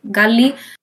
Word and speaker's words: GA11Y: no sé pronunciarlo, GALI, es GA11Y, pero el 11 intenta ser como una L, entonces bueno.